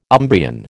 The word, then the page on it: Umbrian: American English